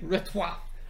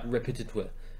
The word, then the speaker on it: repertoire: I heard someone pronounce this so fucking Repertoire. – No, they didn't try to pronounce it French. They just decided to add in an extra syllable where it wasn't, like, they did like repetitoire, and I was like, no, that's not what it is.